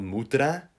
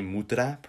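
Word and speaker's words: mutra (мутра): it's called mutra, MUTRA, mutra, pronounced like that,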